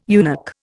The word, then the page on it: eunuch: Indian